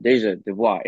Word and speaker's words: Deja Dubois: So then when I got Deja Dubois, it's just four syllables.